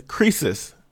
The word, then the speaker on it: Croesus: The next word is croesus. Croesus is spelled C-R-O-E-S-U-S, and it's a noun. – C-R-O-E-S-U-S.